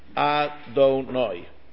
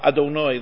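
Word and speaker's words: Adonai (אֲדֹנָי): I'm saying it wrong now, but for the purpose of teaching it, you would say Adonai. But it's a chatov patach. So you say Baruch Atah Adonai Lamdeni Chukecha. Like the Aleph is almost swallowed up, not really swallowed up. You're not allowed to swallow it up. But it's nechtav, it's quick.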